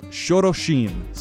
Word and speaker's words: Xorroxin: And speaking of that Euskara pronunciation, I went to a waterfall in Navarra, Spain, that was called Xoroxin, spelt X-O-R-R-O-X-I-N.